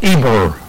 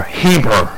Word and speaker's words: Eber: Let me pronounce that for you correctly. It's called Eber or Heber, which is the definitive word of Hebrew.